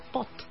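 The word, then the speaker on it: putt: Y cuando ya está cerca para meter al hoyo, se escribe put, pero se dice pot.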